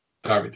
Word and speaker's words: Derby: Or is it pronounced Derby? I want to make sure I pronounce it. Derby, thank you.